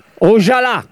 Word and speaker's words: Ojala: Con l'accento sulla A, Ojala.